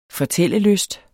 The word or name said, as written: fortællelyst